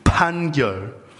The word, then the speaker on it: 판결: 일단 단어는 맞아요. 판결. 이렇게 얘기할게요.